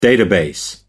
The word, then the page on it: database: American English